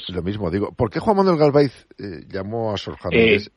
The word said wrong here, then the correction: Galaviz: Sí, antes que nada, una corrección. Es Juan Manuel Galaviz Herrera. – Disculpa, digo Galaviz, la pronunciación.